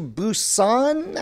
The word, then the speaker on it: Busan: And the sequel to the zombie thriller, Train to Busan, I think I'm saying that right? – That's right.